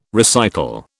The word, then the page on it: recital: British English